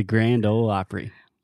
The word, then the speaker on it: Grand Ole Opry: Okay, I'll give you a hint. It's not Ole. ole. – the Grand Ole Opry.